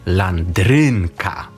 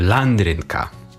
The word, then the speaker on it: landrynka: W języku polskim najczęściej akcentujemy drugą od końca. Tak, kładziemy na nią taki nacisk. Mówimy na przykład... – Landrynka. – Właśnie, mówimy na przykład landrynka, prawda? A góral, gdyby mówił ze swoją intonacją, powiedziałby landrynka, prawda?